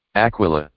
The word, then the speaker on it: Aquila: I think that's pronounced Aquileia. – Aquila. – Aquila.